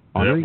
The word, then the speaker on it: Henri: I would just say Henry Jayer. Who knows? – Henry? That works.